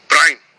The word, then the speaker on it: Brian: My dad would say my name is one syllable. Just go, Brian.